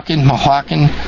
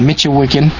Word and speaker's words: Michoacán: He said he's been in one of the Mexican states that the State Department considers dangerous, and I can't pronounce this, M-I-C-H-O-A-C-A-N, Michoacán. It's like their version of Michigan, Michoacán, a few weeks earlier, and the warning is overblown.